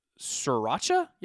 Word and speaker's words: sriracha: Google did an entire section for shortages and so we Googled diesel shortages, baby formula shortages, tampon shortages, Adderall shortages, and sriracha. Yeah, that's how you pronounce that.